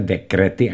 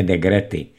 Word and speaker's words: dekrety: Trhá mi uši, když vyslovujete slovo dekrety. Říká se dekrety a ne degrety.